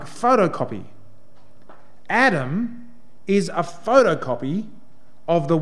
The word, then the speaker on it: Adam: Adam, which in the Hebrew, and if you want to sound like you know what you're talking about when you say Hebrew, you pronounce it Adam.